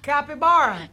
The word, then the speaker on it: capybara: Caipavira? – Capybara? That's how you say it!